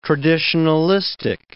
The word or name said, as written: traditionalistic